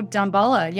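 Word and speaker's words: Damballa: How do you say this? Dambla? – Oh, Dambala. Yeah.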